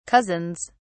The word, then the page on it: cousins: British English